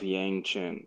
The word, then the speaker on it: Yang Chen: But anyway, it is the Dawn of Yang Chen. That's the name, right? Yeah, Yes. – that's the name. – Yep. – Okay. It's the Dawn of Yang Chen.